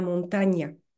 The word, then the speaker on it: montaña: C'est comme on le voit sur l'illustration, c'est un signe en forme de S couché qui se met au-dessus du N et il se prononce nieu. Donc pour dire une montagne, finalement c'est presque comme en français, on va dire una montaña.